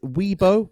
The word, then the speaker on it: weeaboo: Weeaboo.